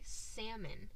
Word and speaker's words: salmon: I'm gonna try and say it correctly this time, but I cannot say salmon. – What do you say? – Salmon. – Oh. – I put the L in it.